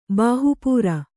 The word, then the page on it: ಬಾಹಪುರಿ: Kannada